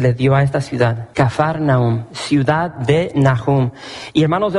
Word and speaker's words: Kafar Nahum: Y hermanos, se nos nombra esta ciudad, la ciudad de Capernaum en sí, la pronunciamos de esa manera, pero es Kafar, así con F, Kafar Nahum. Cuando vas a Israel, como que se te pega un poco el acento de los judíos, escupen mucho. Y es la forma correcta de pronunciar este nombre, Kafar Nahum.